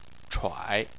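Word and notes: chuai: tone 3

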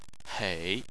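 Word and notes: hei: tone 3

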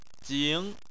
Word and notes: jing: 2

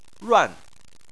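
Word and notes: ruan: tone 4

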